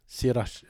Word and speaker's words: Sriracha: Sriracha.